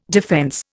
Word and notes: defence: Indian

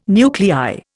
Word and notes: nuclei: Indian

